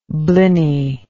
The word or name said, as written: bliny